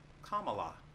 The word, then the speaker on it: Kamala: It is pronounced like comma, Kamala.